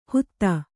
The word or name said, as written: ಹುತ